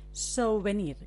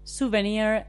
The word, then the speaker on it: souvenir: pero así, souvenir, con esta pronunciación tan maravillosa. Entonces, en lugar de souvenir, decimos souvenir o souvenir.